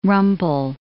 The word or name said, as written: rumple